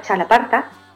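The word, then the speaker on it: txalaparta: aquí en España hay un instrumento que se utiliza mucho en el País Vasco, que es la chalaparta, se llama chalaparta, de X se escribe, y digo aquí, pero son unas tablas que se tocan y con dos malicas, se llaman, ¿vale?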